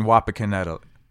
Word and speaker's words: Wapakoneta: Oh, you mean Wapakoneta. Did I pronounce it right? – You did a good job.